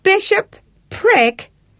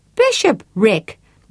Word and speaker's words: bishopric: Bishop prick? No. Bishop Rick, you're not pronouncing it right.